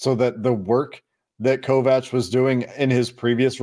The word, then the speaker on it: Kovacs: According to Big Ten Network, it's Kovacs. So we were there's no CH. – Okay. – In the pronunciation there.